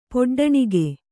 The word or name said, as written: ಪೊಡ್ಡಣಿಗೆ